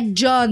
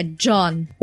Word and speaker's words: Jeanne: e é John mesmo. É John. É assim é a pronúncia do nome dela.